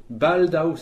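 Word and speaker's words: baldaŭ: Baldao, c'est bientôt. Donc, ça s'écrit B-A-L-D-A-U chapeau.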